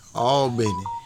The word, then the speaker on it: Albany: All oh, I, I keep saying it wrong. Okay. – Yeah, all Benny. Come on, dude.